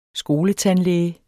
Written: skoletandlæge

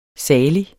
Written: salig

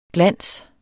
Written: glans

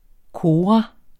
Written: kora

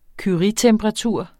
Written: curietemperatur

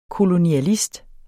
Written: kolonialist

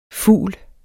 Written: fugl

